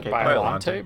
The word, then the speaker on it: Biollante: Some of the things, like, when it does hit a camp, because the story itself, I mean, like, the creature, Biollante? Am I saying that right? – Biollante, but whatever.